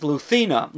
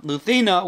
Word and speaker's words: Lucena: which is spelled L-U-C-E-N-A, and is actually, the C is pronounced like a th. It says Luthina. Luthina, which is southern Spain.